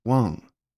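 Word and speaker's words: Wang (王): There are roughly 100 million people named a single symbol, pronounced Wang, for example.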